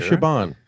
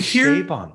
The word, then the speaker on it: Chabon: I pronounce it Chabon. Am I wrong there? – It's actually Chabon.